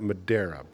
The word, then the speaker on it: Madeira: Right, and I would pronounce that Madeira, but that's okay.